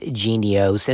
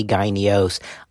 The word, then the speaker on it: Jynneos: Some people pronounce it genios and the others say gynios.